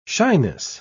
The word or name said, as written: shyness